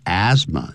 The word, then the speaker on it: asthma: that you will get asthma or depression，你可能会得到所谓呢这个 get 就等于 suffer from 气喘叫 asthma，那个不要念成 asthma 啊，这样 th 不念 asthma，或者是 depression，或者是抑郁症，有人把它叫做忧郁症。